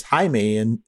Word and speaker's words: Jaime: My name is Jamie. It's spelled J-A-I-M-E, which is Jaime in Spanish.